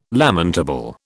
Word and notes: lamentable: British English

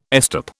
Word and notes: estop: British English

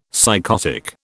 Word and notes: psychotic: British English

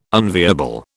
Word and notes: unviable: British English

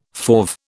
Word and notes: fauve: British English

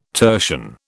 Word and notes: tertian: British English

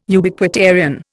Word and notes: Ubiquitarian: Indian

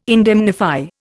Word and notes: indemnify: Indian

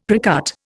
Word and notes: precut: Indian

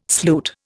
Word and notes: sloot: Indian

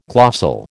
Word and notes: glossal: American English

